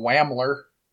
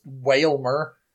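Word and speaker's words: Wailmer: I still pronounce that Pokemon Whamler, even though it's Whalmer.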